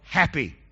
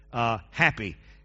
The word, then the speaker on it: Hapi: And then the third one associated with the Nile was called Happy. As I studied how to pronounce that, that's the best way I could come up with Happy. H-A-P-I.